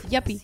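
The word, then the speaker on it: yuppie: Yapi. Yapi, sí, porque pues, se escribe Yupi.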